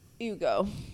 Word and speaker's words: Hugo: They're called Hugo Spritz. – In Italy though, the H is silent. – Hugo.